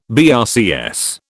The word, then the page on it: BRCS: British English